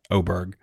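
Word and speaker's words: Åberg: Pronounce Oberg. What did I say? Aberg? Yeah, I think I did. I mean, it looks like Aberg, but you're right, Oberg.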